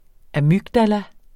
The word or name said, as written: amygdala